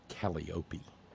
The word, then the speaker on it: Calliope: Of course, we all know that thanks to Anderson Vanderbilt Cooper, the proper pronunciation is Calliope.